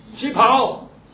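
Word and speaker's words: Qipao (旗袍): How do you pronounce that? Qipao. Adam said it's correct.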